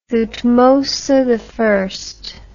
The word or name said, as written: Thutmose I